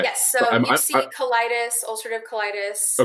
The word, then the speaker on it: ulcerative colitis: So, ulcerative colitis. Am I saying that? – Yes. Right? You're saying that right.